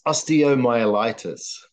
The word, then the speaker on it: osteomyelitis: At it's osteomyelitis.